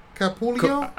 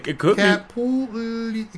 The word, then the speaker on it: Capullo: I'm sure he's had people butcher his name throughout the industry, but I always thought it was Greg Capullo. – Capullo. – Yeah, Greg Capullo. – Because there's 2 L's in it. Capullo, Greg Capullo.